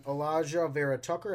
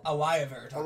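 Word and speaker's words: Alijah Vera-Tucker: I have the Vikings going interior offensive line, and going Elijah Vera Tucker. I think that's how you pronounce it. – Elijah Vera Tucker.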